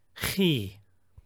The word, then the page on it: chi: Koine Greek